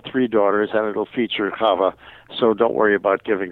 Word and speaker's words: Chava: And, of course, we have the Shava ballet sequence. Was there any thoughts about giving that couple a song? – Yeah, it's actually pronounced Chava. – Chava.